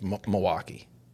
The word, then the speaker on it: Milwaukee: You don't actually pronounce the L's in there, right? It's Milwaukee.